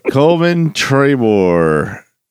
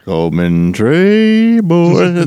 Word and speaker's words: Coleman Trebor: Coleman Trabor. C O L E M A N T R E B O R. – Correct. Yeah. – Coleman Trabor.